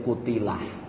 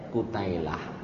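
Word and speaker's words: Kutailah (قتيلة): Nek tulisannya itu ya Kutilah. Bacanya ya Kutailah. Nek wong Jawa manggile dipedot-pedot.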